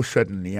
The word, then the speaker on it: certainly: Alors, en parlant de accent, regardez bien pour un mot certainly, accent tombé sur le premier syllabe là.